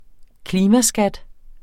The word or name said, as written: klimaskat